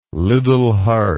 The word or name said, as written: liddel hart